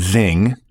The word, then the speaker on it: Xing: and Zing, and it's spelled X-I-N-G, in Europe.